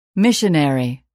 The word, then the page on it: missionary: [míʃənèri]